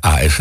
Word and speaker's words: As: Het origineel van S moet je altijd even voorzichtig uitspreken. Het is niet met dubbel S natuurlijk, hè, AS.